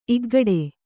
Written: ಇಗ್ಗಡೆ

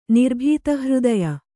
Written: ನಿರ್ಭೀತಹೃದಯೆ

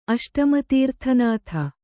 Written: ಅಷ್ಟಮತೀರ್ಥನಾಥ

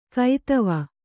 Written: ಕೈದವ

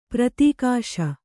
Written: ಪ್ರತಿಘೋಷ

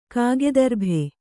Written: ಕಾಗೆದರ್ಭೆ

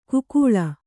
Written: ಕುಕೂಳ

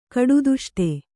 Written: ಕಡುದುಷ್ಟೆ

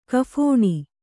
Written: ಕಫಣಿ

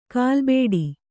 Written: ಕಲ್ಲುಬೆಟ್ಟ

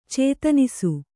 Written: ಚೇತನಿಸು

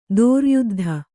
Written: ದೋರ್ಯುದ್ಧ

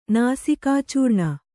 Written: ನಾಸಿಕಾಚೂರ್ಣ

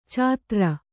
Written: ಚಾತ್ರ

